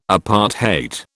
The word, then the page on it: apartheid: British English